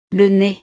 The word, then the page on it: le nez: frances